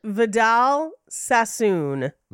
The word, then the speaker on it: Vidal Sassoon: Vidal Sassoon.